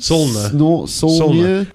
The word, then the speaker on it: Saulnier: The movie was directed and written by Jeremy Solnier? – Solnier. – How'd you spell it? – S-A-U-L-N-I-E-R. Right. Sounds French to me.